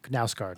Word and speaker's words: Knausgård: It's K N A U S G A with a little circle over it, or maybe two A's, as I say. R D Knausgård.